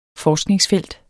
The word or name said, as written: forskningsfelt